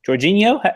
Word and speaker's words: Jorginho: They gave a debut with about half an hour to play to new signing Jorginho.